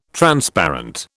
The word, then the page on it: Transparent: British English